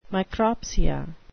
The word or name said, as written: micropsia